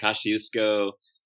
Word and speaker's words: Kosciuszko: And you also climbed to the top of, tell me if I have this word right, Little Kosciuszko, Australia? Is that correct? – There's so many ways to pronounce it. Kosciuszko, Yeah, the Australians say it differently, but yeah, it's the tallest peak in the mainland of Australia, which isn't actually that high.